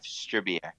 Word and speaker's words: Stribiak: Jeff Stribiak. – Stribiak. – Yes, sir. – Okay. It sounds like Tridiak, who was a goalie for the Russians in the 70s and 80s, but okay, fine.